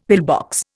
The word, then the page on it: pillbox: Indian